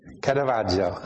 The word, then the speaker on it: Caravaggio: Caravaggio.